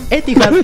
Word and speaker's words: Etihad: El Manchester City le ganó 2-0 al Napoli en la vuelta de Kevin De Bruyne al Etihad Stadium. – ¿A dónde? ¿Perdón? – Etihad Stadium. – Etihad Stadium.